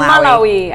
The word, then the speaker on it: Malawi: I was just, I don't know how to pronounce Malawi. Malawi, I like Malawi.